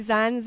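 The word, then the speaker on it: زن: Can I say Zen, Zendegi, and Azadi? I don't know if I said it right. – You said it correctly. Zen, Zendegi, Azadi.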